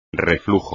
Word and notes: reflujo: espagnol

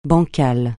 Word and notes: bancal: francais

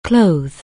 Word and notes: clothe: anglais

